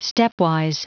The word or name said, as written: stepwise